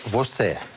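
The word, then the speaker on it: você: Вот это читается «восе».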